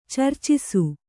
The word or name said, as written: ಚರ್ಚಿಸು